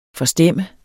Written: FORSTEMMER

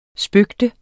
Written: spøge

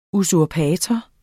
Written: usurpator

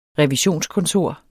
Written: revisionskontor

